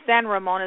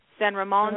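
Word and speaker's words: San Ramón: So um, in the Mexican, um, uh, you'd say San Ramon is how you say Saint Raymond. San Ramon, mm-hmm.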